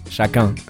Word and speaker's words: Chacun: Es que Chacun suena muy buscarás. – ¿Cómo se dice? – Chacun. – Chacun Porzua. – Está bastante bien. Solo que el Chacun ha sonado muy buscarás y me ha gustado.